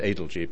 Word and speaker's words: Adelji: And it's Adelji, because all Parsi names are stressed on the first syllable.